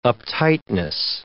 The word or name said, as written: uptightness